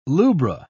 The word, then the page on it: lubra: British English